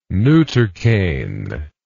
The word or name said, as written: neutercane